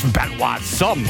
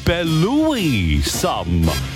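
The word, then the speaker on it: Belouis Some: Der heißt einfach nicht Benoit Somme oder sowas, nein. Es ist eigentlich ausgesprochen Belouis Somme. So wie Brother Louis.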